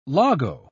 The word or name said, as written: logo